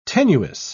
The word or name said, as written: tenuis